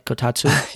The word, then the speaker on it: Kotatsu: Did I say that right? Kotatsu. yeah, Kotatsu.